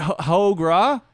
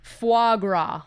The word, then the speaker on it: Foie gras: Ho gras? – Foie gras. – Foie gras. How do you spell that? – F-O-I-S-G-R-A-S.